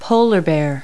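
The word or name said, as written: polar bear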